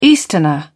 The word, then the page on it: easterner: British English